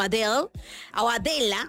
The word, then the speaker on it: Adela: Y bueno, pues llega a posicionarse en la número uno Adela Georgieva, mejor conocida simplemente como Adela, ahí sí lo dije bien. Adela, con acento en la E.